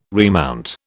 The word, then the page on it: remount: British English